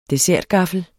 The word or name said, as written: dessertgaffel